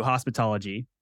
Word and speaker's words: Hospitalogy: And so now we rebranded the Healthy Muse, RIP, to Hospitalogy, and it's spelled hospital, O-G-Y.